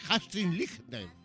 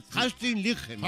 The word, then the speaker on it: Astrid Lindgren: Que es basada en las novelas de Astrid Lindgren. – Qué bien lo has pronunciado, compañero.